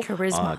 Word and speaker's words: charisma: 그리고 참고로 우리가 흔히 알고 있는 카리스마 이거는 charisma 이런 식으로 charisma. – charisma라고 mm-hmm. 발음이 좀 다릅니다.